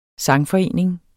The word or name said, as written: sangforening